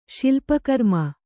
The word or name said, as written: ಶಿಲ್ಪಕರ್ಮ